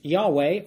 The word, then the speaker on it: Yahweh: And that Yod-Heh-Vav-Heh, or sometimes pronounced Yahweh or Jehovah, was Elohim, and that Yod-Heh-Vav-Heh, or Yahweh, Father, by his Holy Spirit, worked together both with and through Yeshua as one.